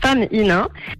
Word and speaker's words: Fem in 1: C'est quoi alors exactement Alors, nous, on le prononce femme INA.